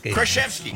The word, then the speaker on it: Krasinski: Did I pronounce it wrong all the time? – Krasinski. Yeah, there you got it.